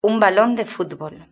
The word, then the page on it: un balon de futbol: European Spanish